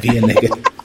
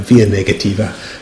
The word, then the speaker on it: via negativa: And the other thing is that this via negative, I'm going to say it wrong, via negativa. I think you need a sort of slightly less Presbyterian accent to do that one, via negativa.